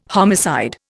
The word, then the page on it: homicide: Indian